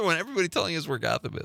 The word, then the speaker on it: Gotham: It's pronounced Gotham, not Gotham. Boy, you really riled some people up tonight, Connor. – Gotham. I'll never pronounce it the other way now. – Monica from Mount Horeb says Gotham is kind of like, I was kind of by Richland Center, but I believe they say it with a hard O. Go Tham.